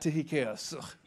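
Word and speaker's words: Tychicus: Tychius. If you think I'm pronouncing that wrong, you need to go to Bible college.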